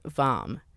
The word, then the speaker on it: Hvam: One Norwegian in particular stood out Hjalmar Hvam. Both his first and last names begin with a silent H.